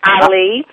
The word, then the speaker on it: Ali: You said it correct, Ali. – Uh, Ali. – like Muhammad Ali, uh-huh.